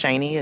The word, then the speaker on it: Shaney: My name is Shani. It's spelled S-H-A-N-E-Y.